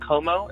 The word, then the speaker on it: Como: Is his last name, is it Como? Am I pronouncing it right? – Yes. That's pronounced right.